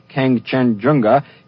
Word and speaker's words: Kangchenjunga: The most difficult peak, also the most tricky to pronounce, Kangchenjunga, is only 800 feet lower than Mount Everest and lies on the border of India and Nepal.